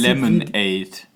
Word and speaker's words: Lemonaid: Lemonade. – Genau, also ausgesprochen wie halt die normale Limonade, aber anders geschrieben.